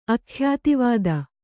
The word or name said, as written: ಅಖ್ಯಾತಿವಾದ